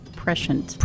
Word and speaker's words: prescient: Prescient. – Prescient. – Prescient. – The UK pronunciation, there's a little update for you there. The UK pronunciation is prescient.